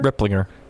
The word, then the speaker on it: Ripplinger: Is it Ring Ripplinger? – Ripplinger. – Is it? Oh, I thought it would have been the soft G, but Turner Ripplinger, he signed a deal with Maine.